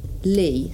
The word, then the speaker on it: lea: It's pronounced lee, so L-E-A, like a clearing in a way.